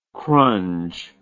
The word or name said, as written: crunge